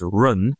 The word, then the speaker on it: jen: The goal is, the spelling is J-E-N, but it's pronounced run, is the superior man.